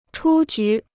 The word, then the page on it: 出局: chū jú